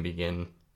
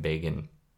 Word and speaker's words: Begin: I was saying Menachem Begin, as how to pronounce their name, the president of Israel in the mid-70s, who was part of a terrorist organization before Israel was formed that had murdered hundreds of Arabs in Palestinian land, or Palestinians. It's actually pronounced Menachem Begin, if I'm pronouncing that right.